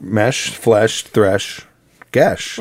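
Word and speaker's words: geis: I'm not sure if I've ever said it out loud, but in my head I've always pronounced it geas. But that rhymes with, says it rhymes with flesh, mesh, and thresh, among other words. So it's gesh.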